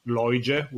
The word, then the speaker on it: Loige: My nickname is Loige, which you will spell it as L-O-I-G-E.